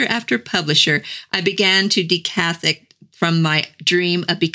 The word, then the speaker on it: decathect: It's spelled D-E-C-A-T-H-E-C-T.